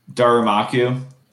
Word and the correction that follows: Darumaka: How can you never pronounce that name right your first try? – Did I just fuck it up? – Yeah. – Yeah, it's Darumaka.